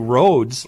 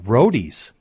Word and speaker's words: Rhodes: I've been saying roads, like R-H-O-D-E-S. – Mm-hmm. – I like the way it's supposed to sound. Roadies. – Oh, okay. – Roadies, yeah. It's Roadies Midway Bar and Grill there in Long Prairie.